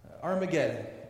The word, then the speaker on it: Armageddon: Armageddon. This is the Greek pronunciation of the Hebrew Har Megiddo.